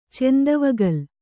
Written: ಚೆಂದವಗಲ್

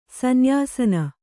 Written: ಸನ್ಯಸನ

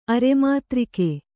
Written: ಅರೆಮಾತ್ರಿಕೆ

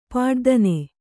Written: ಪಡಿದನಿ

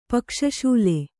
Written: ಪಕ್ಷಶೂಲೆ

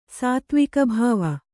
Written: ಸಾತ್ತ್ವಿಕಭಾವ

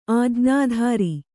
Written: ಆಜ್ಞಾಧರ

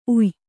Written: ಉಯಿ

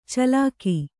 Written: ಚಿಲಕೆ

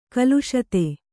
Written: ಕ್ಲಿಶಿತ

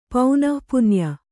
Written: ಪೌನಃಪುನ್ಯ

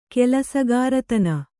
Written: ಕೆಲಸಗಾರತನ

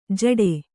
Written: ಜಟೆ

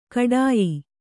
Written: ಖೇಡ್ಯ

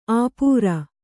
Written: ಆಪೂರ

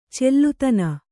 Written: ಚಲ್ಲುತನ

